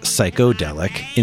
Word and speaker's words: psychedelic: The lyrics included the first use of the term psychedelic, here pronounced psychodelic, in popular music my psychedelic feet in my psychedelic shoes.